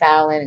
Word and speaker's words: Fallon: That is OU Thought Fallon, and you spell it FALLON.